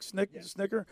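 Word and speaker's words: Snitker: And you know, even the interview, even the post game interview with Snicker, am I saying that right, Nick? Snicker? – Yes.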